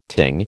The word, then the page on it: ting: American English